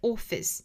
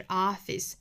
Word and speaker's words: office: Office, 注意元音 office, 英音是 office, 美音是 office, 舌高点更低了。